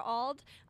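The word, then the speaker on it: Auld: I am your evening host, Sarah Ald.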